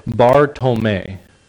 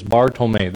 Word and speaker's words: Bartolomei: And so for his last name, this is actually how it's said. It's called Bartolomei. Okay, so it's Bartolomei.